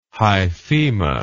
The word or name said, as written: hyphema